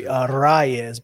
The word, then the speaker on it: Arraez: Arraez. – Yeah. – Luis Arraez. – I believe it's really Arraez, but I can't roll my R's.